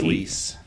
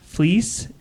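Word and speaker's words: Fleecs: It's written by Tony Fleeks. Is that how you say his name? – Fleece. – Fleece.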